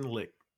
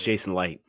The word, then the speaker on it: Licht: You get to be Jason Lick. Like, is it Licht or Light? I don't know how to say it. – It's Jason Light. Jason Light.